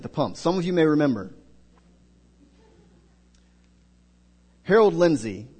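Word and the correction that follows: Lindsell: was the editor of Christianity Today. Mr. Lindzel, I said it wrong the first time, Mr. Lindzel suggested a proposal for solving the gas problem.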